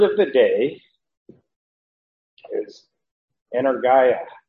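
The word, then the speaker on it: energia (ἐνέργεια): Some might pronounce it energia, but in the Greek, you know, when we look at our pronunciation guide and you have the epsilon iota, it suggests we pronounce that as a long I, as a height. And so we're going to try it. Pronounce it that way, energia.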